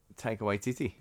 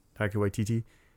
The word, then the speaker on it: Taika Waititi: Taika Waititi. – Yeah, Taika Waititi.